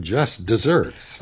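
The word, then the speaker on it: just deserts: And that is confusingly pronounced just like the dessert with ice cream or whatever.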